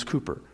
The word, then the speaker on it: Cowper: And then you say, his name's not Cooper, it's Cowper, C-O-W-P-E-R. No, actually, the way that they would pronounce it is Cooper.